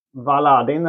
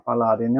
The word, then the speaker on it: Baladin: Birra Valadin eller Baladin. – Baladin, ja.